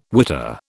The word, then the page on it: witter: British English